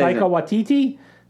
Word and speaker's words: Taika Waititi: How do you say Taika Waititi? – Thank you.